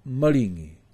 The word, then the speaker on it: Maringi: There are certain words in this section which have all short vowels. They are commonly mispronounced. So please pay attention. Maringi. Maringi. Never ever say maringi.